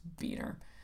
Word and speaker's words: Beuhner: I'm 95% certain that Mark pronounces his last name Beener. Now, it's spelled B-E-U-H-N-E-R, and I was saying Buner. – Sure. – Which sounds kind of like Bueller. Ferris Bueller. It's Beener.